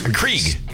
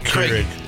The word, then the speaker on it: Keurig: Johnny brought in a coffee maker because Krieg. – Keurig.